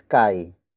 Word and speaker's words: kay: Naroon is spelled as N-A-R-O-O-N. It means there, indicating that the focus is not located here, but there. Up next is kai. Kai. Kai. Kai is spelled as K-A-Y.